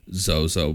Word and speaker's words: Zoso: But there is a book in the 1600s, an occult book, that references a god of Saturn named Zozo, but it's spelled Z-O-S-O.